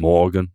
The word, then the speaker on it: Morgen: Morgen, M-O-R-J-N. – Morgen. – Like Morgen. – Yeah, but it's like a soft G sound.